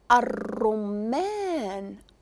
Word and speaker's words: الرمان: حرف الراء إلا انتبهتوا هنا نطقت الألف وما نطقتش اللام. الراء. وكمثال أشنو شفنا؟ شفنا الفاكهة المباركة اللي قلنا مذكورة في القرآن الرمان. الرمان. شفتوني نقزت من الألف للراء والراء كيف قاع الحروف هادو الشمسية درت لها شدة.